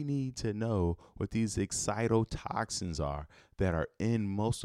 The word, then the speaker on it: excitotoxins: Excitotoxins. I'm sorry. I'm mispronouncing everything this morning. Excitotoxins.